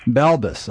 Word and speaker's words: Balbes: Balbes.